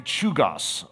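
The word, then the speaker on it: Czolgosz: Robert Mary says it this way: "When McKinley entered through a side door, Leon," how do you pronounce his name? Chugos.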